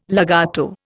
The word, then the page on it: legato: Indian